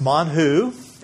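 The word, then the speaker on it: man hu: So remember that the manna, which actually is pronounced manhu, and in Hebrew it actually means, what is it?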